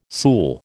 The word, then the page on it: sool: American English